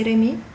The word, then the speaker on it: Remy: Well, it's French, but I'm not from the French-speaking side of Switzerland, so you just say Remy.